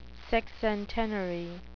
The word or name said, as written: sexcentenary